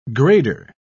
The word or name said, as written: grader